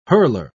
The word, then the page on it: hurler: British English